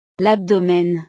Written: l'abdomen

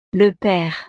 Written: le pere

